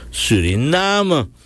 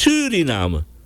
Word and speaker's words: Suriname: Ja, het is Suriname. Die klemtoon is op die namen en niet Suriname.